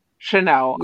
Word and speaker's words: Chanel: Oh my gosh, like Chantel's beautiful. That's how you pronounce it, right? – Chanel. – Chanel. Yeah. I'm so bad.